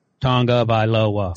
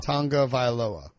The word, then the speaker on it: Tagovailoa: How do you say Tua's last name? – Tagovailoa. – Tagovailoa. Okay. Right. Tagovailoa.